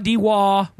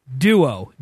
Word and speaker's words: Dewoh: It's spelled D-E-W-O-H. There's no dot unless you're accenting it with a period. D-E-W-O-H. Like the dew on a leaf and O-O-H. It's not D-Waw. We don't sell pizzas. Duo. D-E-W-O-H.